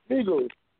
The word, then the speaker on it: Migos: Migos.